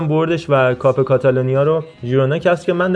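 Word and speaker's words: Girona: حالا چرا ژیرونا خیرونا اینا کلا تو منطقه کاتالونیا زبان کاتالان اون جی اول و ژ میخونن اگه همین بره تو زبان کاستیان یا زبان مادریدی خ تلفظ میشه یعنی اگر ژیرونا باشگاه مادریدی بود میگفتیم خیرونا مثل خطافه که اولش جیه ولی چون مال تیم شهر مادریده میگیم خطافه نمیگیم ژتافه ولی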